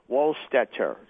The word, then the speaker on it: Wohlstetter: All right, we're talking right now with John Wohlstetter. That is spelled W-O-H-L-S-E-T-T-E-R. – S-T-E-T-T-E-R, Wohlstetter.